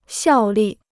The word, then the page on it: 效力: xiào lì